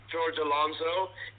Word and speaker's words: George Alonso: Again, it looks like you've been gone for 2 weeks and some change because clearly you don't know how to say my name. It's Dashing George Alonso, and before we even continue, today I am actually tasting a guava margarita.